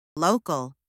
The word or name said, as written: local